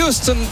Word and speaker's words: Houston: Houston, Texas, évidemment.